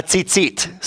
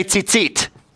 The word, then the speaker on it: tzitzit: And each one of those tassels is called a tzitzit. Say tzitzit. Now say it like you never heard it before. Tzitzit. Okay. If you say it plural, it's tzitziot.